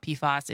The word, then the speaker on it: PFAS: Google says, by the way, it's PFAS with a short A.